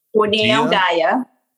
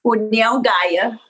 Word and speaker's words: União Gaia: Is it UNAIO? – União Gaia. – Say it one more time. – União Gaia. – União Gaia. Thank you.